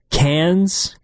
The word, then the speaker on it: Cannes: Is it Cannes.